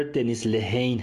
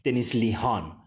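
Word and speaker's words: Dennis Lehane: اولین چیزی که میخوام درباره این نویسنده و کتابش بگم اینه که تلفظ صحیح نام نویسنده کتاب جزیره شاتر دنیس لهین هست و نه اونطور که من بعضی جاها دیدم نوشته شده دنیس لیهان دنیس لیهان غلطه و دنیس لهین درسته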